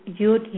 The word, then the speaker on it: Yud: Yud, Yud, Yud. Okay, it's just Y-U-D, Y-U-D, Y-U-D.